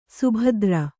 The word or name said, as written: ಸುಬೇದಾರ